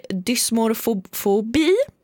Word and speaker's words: dysmorfobi: Det är dysmorfobi. Dysmorfobi. – Ja, lite bättre.